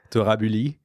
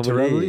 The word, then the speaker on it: Torabully: You can pronounce it in French. It would sound like Torabully. – Oh, Torabully. – But Torabully is fine too.